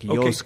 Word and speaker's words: Yosuke: So there is a u. But like when you pronounce it, it becomes like Yosuke.